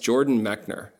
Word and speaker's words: Jordan Mechner: We have on the podcast Jordan Mechner.